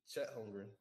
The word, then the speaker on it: Chet Holmgren: We picked Chet Holmgren, right? Am I pronouncing his name right? Pretty sure I am.